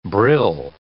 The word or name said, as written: brill